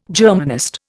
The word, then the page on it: Germanist: Indian